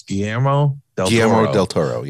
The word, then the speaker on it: Guillermo del Toro: You know, I always pronounce his name wrong. It's Guillermo del Toro.